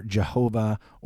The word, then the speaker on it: Jehovah: We pronounce it often either Jehovah or Yahweh.